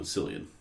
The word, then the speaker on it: Cillian: Oh, Cillian. – I think it's a hard C.